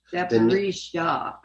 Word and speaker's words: Zaporizhzhia: I don't know Russian, but I heard them pronounce Zaporizhzhia. I don't think there's a T in there.